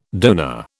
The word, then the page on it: dona: British English